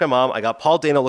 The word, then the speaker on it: Dano: And Paul Dano, who's often, by the way, his name is pronounced Paul Dano. People always screwed up. I read this article. He said it's Dano like Drano. So people will think I'm screwing it up, but I'm saying it right.